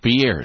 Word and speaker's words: Pierre: Pierre, and it's actually pronounced Pierre, South Dakota. – Correct.